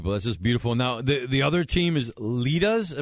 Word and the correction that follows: Lydia: Am I saying it right? Lita's Legends? – Lydia. – Yeah. Okay, I'm sorry.